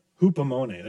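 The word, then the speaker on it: Hupomone (ὑπομονή): It's the Greek word hupomenos. I don't know if I'm saying that right. But it's this staying under pressure. Staying under pressure. What was it? Hupomone. There we go.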